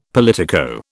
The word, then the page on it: politico: British English